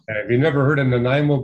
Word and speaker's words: Nanaimo: Ernie Jerome in, I'm going to butcher this name, Nanaimo, British Columbia. – Oh, Nanaimo. Yeah, no, I had relatives who lived there. I know where Nanaimo is.